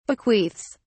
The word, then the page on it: bequeaths: British English